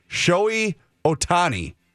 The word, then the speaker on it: Shohei Ohtani: They have uh, reached an agreement, and it's only important because Manny, help me with the pronunciation. Shohei Ohtani did I did I get that right? I think that's pretty close.